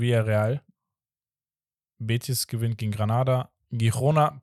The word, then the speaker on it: Girona: Guck mal, ihr habt das auch letzte Folge gemacht und ich weiß, ihr frontet mich immer gerne wegen irgendwelchen Sachen, aber Girona ist absolut falsch ausgesprochen. Schon seit Ewigkeit. – Girona.